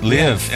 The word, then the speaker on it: Liev: Are we saying that right, Liev? – Live. L-I-E-V.